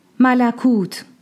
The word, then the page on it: Malakūt: Angelic Realm